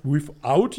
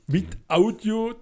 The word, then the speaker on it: without: Das heißt without you, nicht without you. Without. With Audio, das ist falsch ausgesprochen und da ist die Zunge irgendwie im Weg.